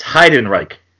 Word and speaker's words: Heidenreich: It's Heidenreich.